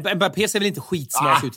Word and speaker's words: Mbappé: Ja, Mbappé, rätt uttal.